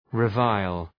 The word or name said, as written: revile